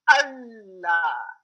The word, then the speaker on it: Allah: It's not Allah for God. It's Allah. Two L's.